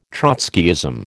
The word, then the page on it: Trotskyism: American English